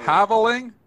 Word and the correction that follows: halving: How do you say it, Spencer? – The halving. You were right the first time.